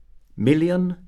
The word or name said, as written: million